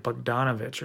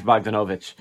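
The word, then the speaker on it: Bogdanović: Why did I always think it was like Bogdanovich or something? – Because that's the way I used to pronounce it all the time. but I'm pretty sure it's Bogdanovich.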